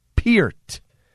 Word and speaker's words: Peart: And again, it's pronounced Peart.